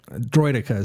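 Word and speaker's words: Droideka: Is it Droidica or Droideca? – Droidica is how they say it in the film.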